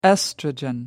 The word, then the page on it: oestrogen: American English